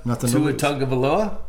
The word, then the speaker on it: Tua Tagovailoa: What about Tua Tagovailoa? – Oh, my gosh, you got the name right.